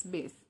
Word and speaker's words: Bass: N-A-I-R-O-B-I-B-A-S-S. It's bass, like the bass guitar, right?